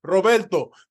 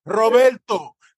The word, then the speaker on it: Roberto: And I'm like, hey, my name is Roberto. There's an O at the end. And yes, you should try to roll your R because my name is Roberto, not Robert, not Bob, not Bobby, Roberto.